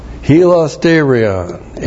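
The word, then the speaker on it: Hilasterion (ἱλαστήριον): I think I did a good job of messing that up. Ta-da! Hilasterion, H-I-L-A-S-T, long E-R-I-O-N, Hilasterion.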